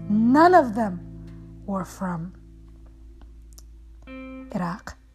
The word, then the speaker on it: Iraq: This man, this leader, this president in a country, many people would pronounce it as Iraq, but it's actually Iraq.